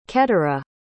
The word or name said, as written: qeturah